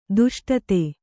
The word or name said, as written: ದಷ್ಟತೆ